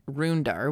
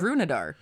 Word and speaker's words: Runedar: You said Rundar, which is it is 100%. – The Siege of Rundar. – When I read it, I pronounced the E. So I went like, I was like, the Siege of Runadar. But no, it's probably about runes.